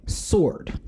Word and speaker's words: sword: So as a public service announcement, it is pronounced sword. The W is silent.